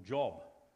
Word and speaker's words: Job: So let's hear about Job, or Job. So it's usually pronounced as Job.